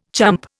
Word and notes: chump: Indian

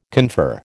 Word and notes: confer: American English

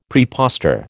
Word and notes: prepostor: American English

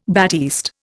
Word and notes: batiste: Indian

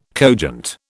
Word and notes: cogent: British English

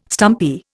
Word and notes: stumpy: Indian

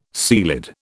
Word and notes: ceilidh: British English

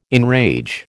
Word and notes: enrage: American English

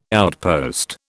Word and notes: outpost: British English

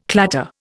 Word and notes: clatter: Indian

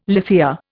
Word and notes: lithia: Indian

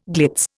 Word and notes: glitz: Indian

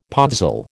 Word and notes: podzol: American English

